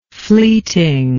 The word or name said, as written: fleetingness